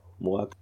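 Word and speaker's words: Moac: Und ähm, seine Rolle hier ist Moak, spricht man das so aus? Moak. – Ja, ja.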